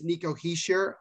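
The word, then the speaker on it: Hischier: But it is Nico Heischer, as most people pronounce it. That's the way that he pronounces it.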